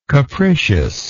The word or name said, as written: capriciousness